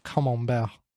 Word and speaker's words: Camembert: Which is why I say it now, you know, Camembert.